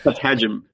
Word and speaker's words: patagium: So you've got a paper coming out in Nature that concerns something, and I'm not quite sure how to pronounce it. Is it Patagium? Patagium? – Patagium. – Patagium. All right, I was completely off.